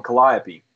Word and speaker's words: Calliope: say it wrong, Calliope. – No, you said it right. – You said it wrong. – You said it exactly right. – It's Calliope.